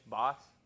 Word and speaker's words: Boss: RIP to Twitch Degenerous, or Twitch Degenerous. – What? – So there was a... – Steve Twitch Boss? – Steve Boss? Twitch Boss. My bad.